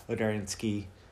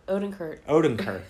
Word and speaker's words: Odenkirk: Bob Odernsky. – What's his name? – Bob Odernsky. – Odenkirk.